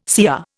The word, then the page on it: CR: Indian